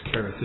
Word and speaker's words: Cherith: It's spelled C-H, but it's Cherith is pronounced.